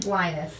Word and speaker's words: Linus: Lionel. – Say his name again correctly. – Linus. – And you got it.